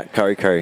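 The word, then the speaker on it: Kurri Kurri: Uh, grew up in Kurri, Kurri. Am I saying that right? Yeah, Kurri, Kurri.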